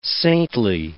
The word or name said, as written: saintly